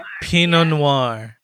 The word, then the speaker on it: Pinot Noir: Pinot Noir. – We are going to be drinking. We are going to be drinking Pinot Noir.